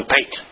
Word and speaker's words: pate: His dealings shall come down on his own pate. Had to change that did you know P-A-T-E actually is pronounced pat?